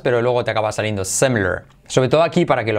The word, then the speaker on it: similar: Similar, pero fíjate cómo se dice. Similar. Ay, como pongo la boca. Similar. Yes, similar.